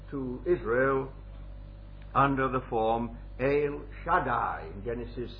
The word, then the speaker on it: El: It's E-L, but apparently it's got a long E and it ought to be pronounced El.